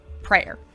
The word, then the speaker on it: prayer: One of the things we've been thinking about a lot lately is the topic of prayer. Prayer, two syllables, as prayer. – I say it.